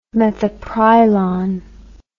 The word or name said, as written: methyprylon